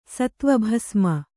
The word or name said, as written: ಸತ್ವಭಸ್ಮ